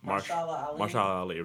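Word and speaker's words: Mahershala Ali: Marshala Ali.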